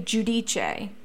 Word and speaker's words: Giudice: Then a couple seasons in, not the first season, a couple seasons in, she clarified that it's actually Giudice, and she was not pronouncing it, or they weren't pronouncing it correctly.